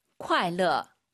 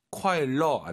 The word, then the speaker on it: 快乐: 快乐. – 콰일러 안 돼요. 르어예요. 콰일러. 근데 여러분, 오늘은 이렇게 외우는 게 아니라 아시는 분은 아실 거예요. 주말 잘 보내세요. – 周末快乐, 생일 축하해.